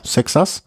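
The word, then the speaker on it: Seixas: Wie spricht man ihn aus? Paul Sanson? – Sexas.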